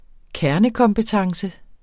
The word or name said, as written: kernekompetence